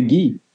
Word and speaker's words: Guy: The spelling is Guy. But in French, we say Guy.